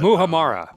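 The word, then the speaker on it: muhammara: Muhamama. Mama humama. How do you say that? – What are you talking about? – I thought it was Muhamara, but... – Muhamara. I forgot an R.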